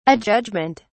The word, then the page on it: adjudgement: British English